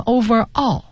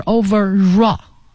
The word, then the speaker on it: overall: 嗯，再往下一个词是 overall 呢，它是一个组合在一起的，over 和 all 放在一起，有同学念成 overall，那不对，是 overall 啊，它要连在一起读，所以 R 结尾的词加元音开头的这种连读是 raw 啊，所以你会听到 overall 是全部的、总的这个意思，形容词。